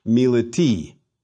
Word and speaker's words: militi: And to put that noun in the dative case, we put the ending I on the stem, a long I, which we pronounce E. So again, first we find the base, which still remains milit, and then we add that long I to the base, militi.